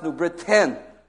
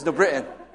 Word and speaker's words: New Britain: It's actually pronounced New Britain with a T, but you grew up there, you don't pronounce the T. It's New Britain.